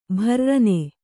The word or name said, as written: ಭುರ್ರನೆ